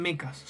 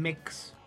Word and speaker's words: mechs: Pero no son mechas, son mechs. – Son mechs, ahí va. Porque los extraterrestres en realidad se dividen en... – Los que pronuncian la A y los que no.